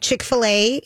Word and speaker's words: Chick-fil-A: So for people who love, I don't know that Chick-fil-A, did you see how I pronounced it right now? – Yeah.